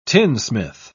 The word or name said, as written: tinsmith